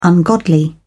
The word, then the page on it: ungodly: British English